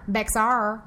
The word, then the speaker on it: Bexar: Bexar County? – Bexar County.